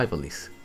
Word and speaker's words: Ivalice: El juego contiene cameos de personajes centrales y de apoyo de Final Fantasy XII, un título que sucede en Ivalice, o Ivalis, como se pronuncia.